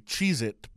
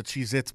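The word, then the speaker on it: Cheez-It: And thank you for saying it properly. Cheez It Bowl, yeah. not the Cheez Its Bowl.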